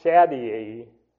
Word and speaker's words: Shaddai: And the Almighty is from Strong's 7706, and it's pronounced Shaddai, spelled Shaddai, and it's from the Old Testament, Strong's number 7703, which means powerful, the Almighty.